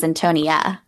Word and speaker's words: Antonia: And this is really bringing out a lot, including Antonio from Top Chef. – And I thought her name was Antonia.